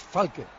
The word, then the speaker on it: Falque: Lleva toda la vida llamándole, apellidándole Falque, y es Falque. – Pues te digo una cosa, tiene razón, ¿eh? Porque no hay ningún acento en la E.